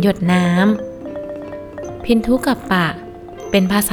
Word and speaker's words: พินทุกัปปะ: สวัสดีค่ะพบกับรายการคำวัดสำหรับวันนี้เสนอคำว่าพินทุกับปะ